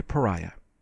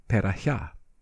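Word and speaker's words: Perahia: 5 in E, the Harmonious Blacksmith, played by the great Murray Pariah. I once knew a Sephardic couple. They said the name should really be pronounced Perahiah.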